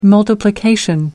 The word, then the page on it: multiplication: British English